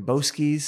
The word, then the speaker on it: Boesky: Which what I read about, and you spoke his name earlier, was inspired by Ivan Boesky's speech. – I think it's Boesky. I might have pronounced it wrong earlier. Yeah. I've always said it's Boesky.